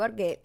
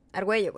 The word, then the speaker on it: Argüello: Si tú lo escribes mal, para eso tenemos eso. Argüello. Argüello, güey.